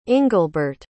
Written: ingelbert

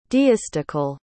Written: deistical

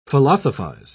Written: philosophize

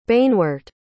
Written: banewort

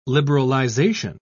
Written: liberalization

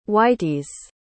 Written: whiteys

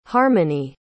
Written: harmonie

